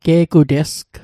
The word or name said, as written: ge'gutesg